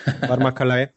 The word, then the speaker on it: Vamakalai: You pronounced that very, very nicely, very well, Vamakalai.